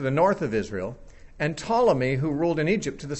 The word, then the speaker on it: Ptolemy: And then Ptolemy, spelled with a silent P, P-T-O-L-E-M-Y, Ptolemy reigned in Egypt.